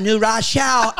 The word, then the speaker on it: New Rochelle: The last time I saw you, I think it was in New Rochelle. – It was White Plains. Why do you say it like that? It's New Rochelle.